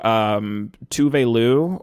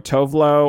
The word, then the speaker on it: Tove Lo: and everyone has been listening to Tove Lo or Tove Lo or apparently she doesn't care how you pronounce her name.